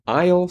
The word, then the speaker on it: Isle: I had not heard of 20 across, New Jersey's C blank city, aisle C. That sounds like something you would hear in a Kmart ad. In aisle C, no, but it's spelled I-S-L-E.